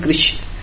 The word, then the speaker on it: Krishna: So Krishna becomes Krishna. K-R-I-S-H-N-A. So that A is not to be pronounced loudly, not to be emphasized over there.